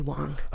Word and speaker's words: Wang: I think it's Wenwei Wang. – It's actually Wong. – Oh, okay. – We've been doing it wrong for years. It's spelled Wang, but it's pronounced Wong.